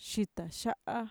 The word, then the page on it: shitaxa'a: Mixtec